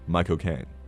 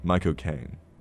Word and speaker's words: Michael Caine: Because you're supposed to say it as my cocaine, right? So it's supposed to be Michael Caine. Michael Caine.